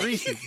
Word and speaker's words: Reese's: Reese cups. – Say it again for me. I have my mouth. – Reese's. It's Reese's. The Reese's cup. – No, you and Nate. – It's Reese's.